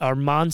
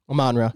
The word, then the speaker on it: Amon-Ra: Now, is it Amon St. Brown? Am I saying that right? – Amon-Ra.